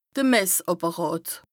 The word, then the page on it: de Messàpperàt: Bas-Rhin; Strasbourg